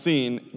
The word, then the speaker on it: Sin: The parallels between the land of sin pronounced seen, being thorny.